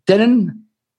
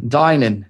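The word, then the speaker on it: Deignan: Elisabeth Dennen. – Dijnen.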